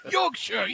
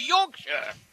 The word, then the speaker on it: Yorkshire: Say it like this. Say like Yorkshire. – Yorkshire. – Yes. You're gasping at your last breath and you say Yorkshire.